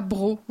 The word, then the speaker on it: broc: D'ailleurs, ça m'a fait penser à bro. Vous savez, un brodo, oui. Un pichet, quoi, qui s'écrit B-R-O-C et qu'on ne dit pas broc. Non, ça se fait pas.